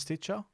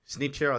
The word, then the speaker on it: Stitcher: I didn't mispronounce Stitcher. – Snitcher, I think he calls it.